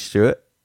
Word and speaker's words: Stuart: Well, you know, everybody can find me at my name, which is Stuart, spelled with UART and ST at the beginning.